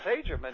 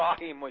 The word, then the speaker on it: Hajim: I mean, I have been Hajim. Most of it sounds much better, but it's Hajim or Ahim if you drop the H, you know.